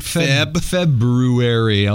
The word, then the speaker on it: February: February. I'll even pronounce the R there for you.